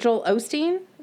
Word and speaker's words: Joel Osteen: Like, is it Joel Osteen?